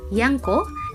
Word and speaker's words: Yangko: Dans la prononciation javanaise, le mot était alors prononcé comme Yangko, qui devenait largement connu du public en 1939.